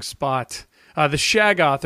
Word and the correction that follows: Shoggoth: or the Sogoth, or whatever. What's it called? How do you pronounce it? Shoggoth.